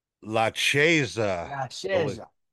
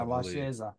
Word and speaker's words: La Chiesa: I don't know how to say it in Italian, so sorry. – La Chiesa. – Yeah, La Chiesa. That's it.